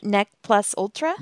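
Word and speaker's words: ne plus ultra: Neck plus ultra? – Neck plus ultra.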